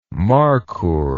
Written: markhoor

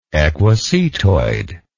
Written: equisetoid

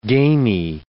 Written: gamy

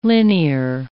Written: linear

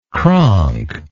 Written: cronk

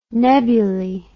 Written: nebuly

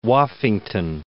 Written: Woffington

